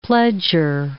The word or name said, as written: pledger